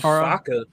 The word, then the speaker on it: Sokka: Not only did they pronounce Aang, Aang, they pronounce Sokka. Sokka. Yeah. – Soka.